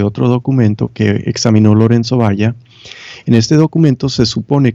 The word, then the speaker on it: Valla: En italiano se dice Lorenzo Valla, pero supongo en español es Valla porque es con L de caballo.